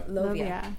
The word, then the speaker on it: Lovia: and the judging panel will be comprised of Eva Lavia? – Mm-hmm. Lovia. – I knew I was butchering that.